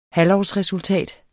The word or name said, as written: halvårsresultat